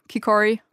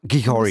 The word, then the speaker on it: Kikori: Ich weiß, dass das Dorf im Dschungel, in dem du gelebt hast, Kikori heißt. Ich hoffe, ich habe es richtig ausgesprochen. – Ja, äh, Kikori. – Kikori. Ein bisschen englische okay.